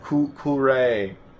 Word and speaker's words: Kuhre: It's like you cure someone. Cure. – Cure? Oh, it's just Cure? – Yeah. – Okay. – K-U-H-R-E.